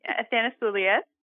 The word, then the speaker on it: Athanasoulias: Athanasoulias. – I think Athanasoulias. – What a beautiful name. – It says it exactly how it looks. So it's just long.